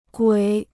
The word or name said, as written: 鬼